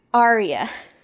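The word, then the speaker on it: Aurea: Aria. Sorry. It's spelled crazy. It's spelled like Borealis.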